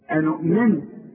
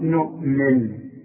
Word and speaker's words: نؤمن: تقول لي أنؤمن تقول لي قالوا أنؤمن لا بالألف دي تبع إلى قالوا قالوا نؤمن خدت بالك نؤمن كلها مرققة